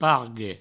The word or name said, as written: pargue